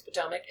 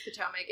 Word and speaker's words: Potomac: Also, I was in Virginia semi recently, and we were talking about the Potomac, or however you want to pronounce it, but I think it's Potomac. It's Potomac. – It's for sure Potomac.